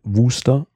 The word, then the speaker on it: Worcester: Was Worcester geschrieben wird, wird Worcester ausgesprochen.